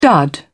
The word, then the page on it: dud: British English